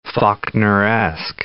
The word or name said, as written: Faulkneresque